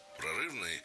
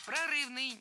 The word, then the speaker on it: прорывной: Отставил четвертую, которая была, ну, опять же, для того времени достаточно такой прорывной. Написал пятую, которая с формальной точки зрения гораздо более, ну, скажем так, классична. – Прорывный, неверно, правильно, прорывной. Ударение в этом прилагательном падает на окончание. Прорывной. Запомнили.